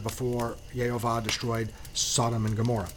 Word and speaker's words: Gomorrah: I have been told that the actual correct pronunciation is Gomorrah. So it's supposed to be a long O first. I've been told that. I've had other people say, no, it's a short O.